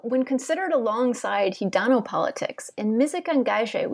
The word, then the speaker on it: musique engagée: But on the other hand, you have traced here a long trajectory of musique engagée. Again, please correct me if I'm mispronouncing it. – Perfect.